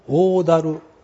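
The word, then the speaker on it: 大滝: それから河津七だる、七つの滝と書きまして七だるという不思議な発音をするんですけれども、河津七だる、天城峠から南流する、河津川上流の約 1.5km の間にある大小 7 つの滝、一番大きいのが大だる、大きな滝と書いて大きな滝と書いて大だる、 30 メートルの落差がある、これが最大の滝です。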